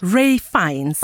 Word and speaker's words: Ralph Fiennes: Ralph Fiennes.